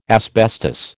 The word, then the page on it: asbestos: American English